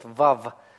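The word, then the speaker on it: вав (וו): Но вместе с тем, хотя мы знаем, что первоначальное произношение было «вау», тем не менее в современном иврите ее произносят «вав», называют «вав» и произносят как «в».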